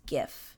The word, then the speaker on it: GIF: Say GIF, I die a little inside. It's GIF. Hard G.